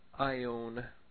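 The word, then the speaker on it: Ion (αἰών): It might look like an A-I-W-V. It's not an A-I-W-V. It's an Alpha, Iota, Omega, Nu. Ion. The Omega is the long O. Ion. The A and the I together make an I sound.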